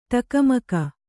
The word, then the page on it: ಢಕ್ಕಾಮುಕ್ಕಿ: ḍhakkāmukki